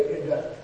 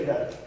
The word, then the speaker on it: Ida: Say Idda. If you want to pronounce it right, you have to turn your tongue back slightly. And that's why Indian people sound like that. Alright? So curve your tongue back and then say Ida.